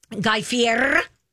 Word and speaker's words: Guy Fieri: Guy Fieri.